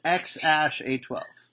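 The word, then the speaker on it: X Æ A-12: And then A12, but the A and the E smashed together, you pronounce Ash. – Right. – Which, so it's XASH12.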